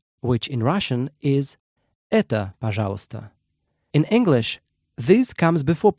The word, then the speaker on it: пожалуйста: In Russian, please is пожалуйста. Пожалуйста. Let's break it down by syllable.